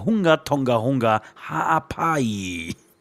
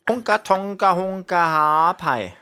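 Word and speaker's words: Hunga Tonga-Hunga Ha'apai: Und jedes Mal hat sie den Namen wirklich richtig korrekt ausgesprochen. Hunga Tonga Hunga Haapai. – Hunga Tonga Hunga Haapai.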